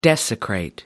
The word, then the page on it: desecrate: American English